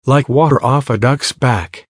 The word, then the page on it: like water off a ducks back: American English